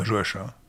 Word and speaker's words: Joachim: Euh, alors, comment prononcez-vous le prénom Joachim Joachim je dirais Joachim.